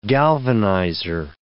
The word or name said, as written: galvanizer